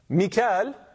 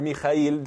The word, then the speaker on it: Mikael: Mikael, or Mikael, or Michael, depending on whether you want to pronounce it more the Hebrew way, etc.